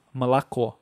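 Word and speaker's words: malakô (молоко): Não, se escreve molocô, tá? Se escreve molocô, mas se fala malakô. Então parece que substitui o O pelo A, mas na verdade é malakô.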